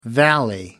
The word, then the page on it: valley: American English